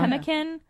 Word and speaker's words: pemmican: It's called like pemmican, I believe it's called. – Oh, yeah. – Pemmican. I can't pronounce it fully. – I know. Is that the pemmican? P-E-M-M-I-C-A-N.